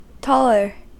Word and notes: taller: American English

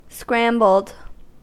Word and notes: scrambled: American English

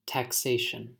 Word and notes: taxation: American English

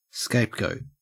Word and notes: scapegoat: Australian English

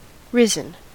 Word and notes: risen: American English

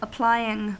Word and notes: applying: American English

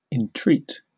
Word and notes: entreat: British English

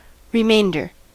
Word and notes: remainder: American English

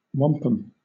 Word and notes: wampum: British English

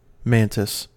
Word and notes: mantis: American English

